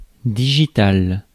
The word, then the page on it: digitale: France; [di.ʒi.tal]